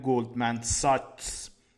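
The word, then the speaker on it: Goldman Sachs: بعضی دوستان دیدم که میگن گلدمن ساکس اشتباه هست تلفظ سعیش گلدمن ساکس هست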